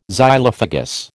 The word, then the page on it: Xylophagous: American English